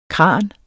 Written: kran

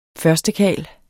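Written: førstekarl

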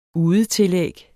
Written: udetillæg

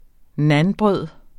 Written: nanbrød

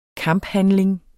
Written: KAMPHANDLING

